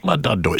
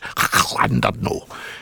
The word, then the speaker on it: Llandudno: So it's nice to be back, a nice little week away down at Wales, down at Llandudno, but no, not Llandudno, it's Llandudno, because it's got two L's, you see, so it's...